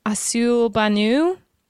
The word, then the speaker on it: Accueil Bonneau: I think it's uh, Accueil Bonneau.